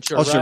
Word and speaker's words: Altucher: And James, is it Altucher? Is that how you say his name? – Altucher, right.